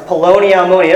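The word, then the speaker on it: Poloni Almoni (פלוני אלמוני): Can try to spell it, but Poloni Almoni is how it's pronounced. Poloni Almoni.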